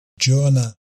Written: Jonah

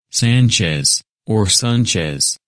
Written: Sanchez

